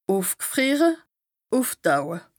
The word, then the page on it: ùfg'frìere, ùftaue: Bas-Rhin; Strasbourg